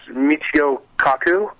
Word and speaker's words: Michio Kaku: It's Michio Kaku.